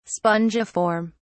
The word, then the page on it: spongiform: British English